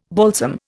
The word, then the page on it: balsam: Indian